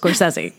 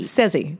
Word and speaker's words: Scorsese: Tell you how to pronounce Scorsese. It's Scorsese. – Scorsese. – Sezzy. Sezzy. – Sezzy. – Mm-hmm. – Okay, got that, everyone? – Yeah. – Sezzy. – Scorsese.